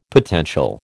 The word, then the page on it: potential: American English